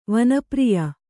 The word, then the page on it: ವನಪ್ರಿಯ: vana priya